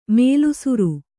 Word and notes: ಮೇಲುಸಿರ್: kannada

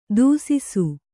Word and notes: ದೂಸಿಸು: dūsisu

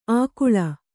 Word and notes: ಆಗುಳ: āguḷa

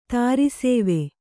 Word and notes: ದಾರಿಸವೆ: dāri save

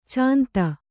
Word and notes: ಛಂದ: Kannada

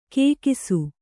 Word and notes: ಕೇಕಿಸು: kēkisu

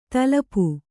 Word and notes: ತಲಪು: talapu

